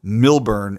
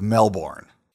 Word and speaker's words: Melbourne: That if I want to be a true Australian, I have to pronounce it Milburn instead of Melbourne.